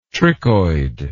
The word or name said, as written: trichoid